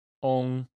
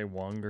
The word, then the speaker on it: Weng: First author is Ong, is how you say it in Chinese, but it's W-E-N-G in English. You probably say Wang or something.